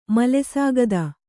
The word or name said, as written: ಮಲೆಸಾಗದ